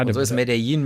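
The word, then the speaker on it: Medellín: Es gibt den, er hat ja in Medellin spricht man es, glaube ich, ne? – Ja, ja. – Also Medellin. – Die Kolumbianer sagen Medellin.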